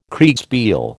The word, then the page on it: Kriegspiel: American English